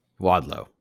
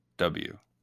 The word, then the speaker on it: Wadlow: Wadlow. – Is that how you say it? Yeah. Oh, there's no E, is it? It's Wadlow.